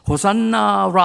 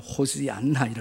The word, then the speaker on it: 호산나: 다 같이 호산나 라바. 네, 정확하게 하면 호시안나 이렇게 정확하게 말하면 그렇게 발음을 하는데 네, 위대한 구원의 주님 그렇게 외쳐요.